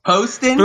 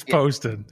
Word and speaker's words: Poston: JT Pastone. – So close. – Poston? – That's good. Yes, it's Poston.